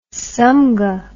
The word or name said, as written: samgha